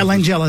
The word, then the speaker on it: Langella: Yeah, Langella, that's how you say it.